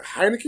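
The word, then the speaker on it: Heinicke: Taylor Heineke, is that how you say his name? Yep.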